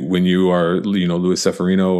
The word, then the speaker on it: Luis Severino: I believe his name is actually pronounced Luis Severino.